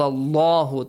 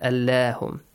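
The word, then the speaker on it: الله: Wa idh akhad Allahu, the letter lam in the word Allah, since it has a fatha before, it should be pronounced heavily. – Allahu, not Allahum.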